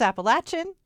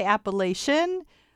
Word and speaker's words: Appalachian: You say Appalachian. Are there several correct pronunciations? Tell us how to say this correctly. – There's no correct, but the two are Appalachian and Appalachian. So it's a regional preference.